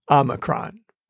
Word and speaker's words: Omicron: If you haven't seen or heard or read them, then spoiler alert, the O is pronounced like the O in not, Omicron.